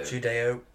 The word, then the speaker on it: Judeo: Do you pronounce that Judo? – Judeo.